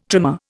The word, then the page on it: trimer: Indian